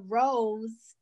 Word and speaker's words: Roze: And Rose is just a play off my last name, Rozoff. So so instead of R-O-S-E, it's R-O-Z-E. So most people mispronounce say Rose, which I think is fine.